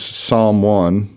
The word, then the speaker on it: Psalm One: And her name is Psalm One, like spelled like the P-S-A-L-M, Psalm One.